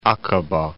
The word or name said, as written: Aqaba